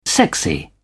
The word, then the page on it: sexy: British English